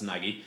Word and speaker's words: Nagy: I always wonder how it's pronounced. That's Nagy.